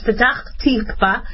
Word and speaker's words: Petach Tikva: And the double infection was first identified in a woman this week at Rabin Medical Center in Ikilov in Petach Tikva. Sorry. Hope I'm pronouncing it right. My father would be mad if I mispronounce it, but it's Petach Tikva.